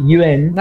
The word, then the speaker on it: yuan: La Y se pronuncia como i, yuan.